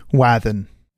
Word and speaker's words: Wathan: Wathan.